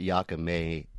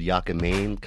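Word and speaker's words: yakamein: Well, honestly, most people don't really call it Yakame. It's really called Yakamane, kind of like lo mein, but people pronounce it different.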